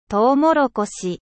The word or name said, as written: mais